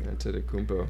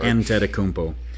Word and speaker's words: Antetokounmpo: Antetokounmpo. It's not that hard. – Antetokounmpo.